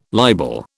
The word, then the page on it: libel: British English